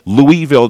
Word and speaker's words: Louisville: The actual pronunciation is that right? – Louisville. It's named after Louis.